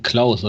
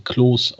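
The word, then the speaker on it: Clauss: Auch mal nachbereitet übrigens, der Verteidiger, der da abgehängt wurde, und jetzt kommt zwar Jonathan Klaus, oder ich glaube, er möchte sich Klos ausgesprochen haben, weil er Franzose ist, und jetzt das eigentlich Skandalöse dabei, das ist einer der schnelleren von Arminia.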